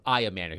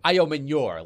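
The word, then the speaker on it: Ayomanor: And I had to get on Jared's case because he was butchering the pronunciation of Ayomanner. He was going Ayomanior, like Manion from Chris Manion, yeah.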